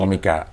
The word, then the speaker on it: Comika: Sebenarnya sih cara bacanya komika, tapi karena platformnya ada comika.id, gue takut orang ketika nulis susah salah.